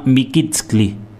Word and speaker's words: Mikitskli: Bueno, entonces vamos a escuchar el tema Mikitskli. ¿Lo dije bien? Sí, Mikitskli, sí.